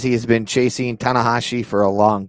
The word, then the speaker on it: Tanahashi: We've got John Moxley coming out to have a face-to-face, and I'm going to butcher his name, so help me out here. Hiroshi Tanahashi? – Tanahashi, pretty close.